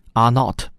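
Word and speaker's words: R naught: 这个指标叫做 R 零，英文发音 R not，中文一般叫基本繁殖数。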